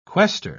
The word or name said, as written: quaestor